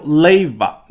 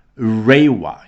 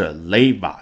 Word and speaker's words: 令和: 首先开头 leva，你已经听出来了，没有读成 rewa，因为啊，日语跟世界上很多的成熟语言主流的，比如说汉语和英语相比呢，缺失很多的音，辅音里面这个 r 它就没有，它只能读成了，那就是 leva。